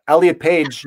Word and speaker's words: Elliot Page: And so when Elliot Page, yes, I said it right. Fuck yeah. All right, cool. Elliot Page, you know.